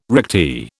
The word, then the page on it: recti: British English